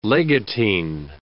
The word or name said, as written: legatine